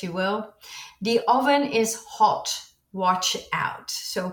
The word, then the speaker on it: oven: Same spelling, slightly different pronunciation. We say oven with a clear O.